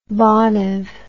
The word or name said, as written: Bonham